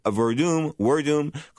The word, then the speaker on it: Werdum: Fabrice Werdum, call him what you want. Some people pronounce it with a W. Some use a V.